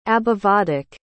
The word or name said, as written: abhivadak